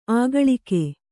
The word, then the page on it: ಆಗಳಿಕೆ: āgaḷike